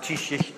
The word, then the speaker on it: Taoiseach: The Irish word then is pronounced Taoiseach, ça pas marché, mais...